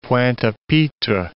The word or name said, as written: Pointe-a-Pitre